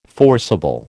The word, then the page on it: forcible: American English